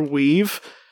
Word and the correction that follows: Weed: I'm not Weave. I said that wrong. Weed,